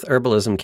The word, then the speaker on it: herbalism: You don't pronounce the H in herbalism, so... – it blows my mind. – Having UK aneurysm. – Yeah. – Well, because it's an herb, not a herb. – It's spelled with a H, my friend. – Yeah, but it's a silent H.